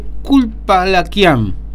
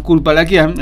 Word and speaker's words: Dzhulfalakian: Se dice Culpalaquian. Se escribe. Usted no quiere saber cómo se escribe esto, ¿no? Se escribe D-Z-H-U-L. F-A-L-A-K-I-A-N. Usted dice, ¿quién es este hombre? Este nombre es armenio, ¿no? Bueno, este hombre, Arsen, lo decimos por fonética, ¿no? Culpalaquian, en realidad.